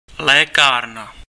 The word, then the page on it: pharmacie: France; /faʁ.ma.si/